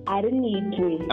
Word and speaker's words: Alinaitwe: Alinaitwe. – I'll have it right with time. – But actually, the actual pronunciation, you know, the Alinaitwe is most modernized English. – Yeah. – The actual pronunciation is Alinaitwe.